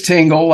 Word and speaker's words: Tingle: it's Tingle, like the sensation.